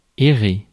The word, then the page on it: errer: France; /ɛ.ʁe/; /ɛ.re/